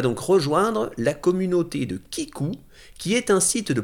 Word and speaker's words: Keeku: Vous aurez donc juste à aller sur Kikou, ça s'écrit K2EKU, e euh,